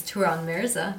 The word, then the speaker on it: Turan Mirza: His name is Turan Mirza.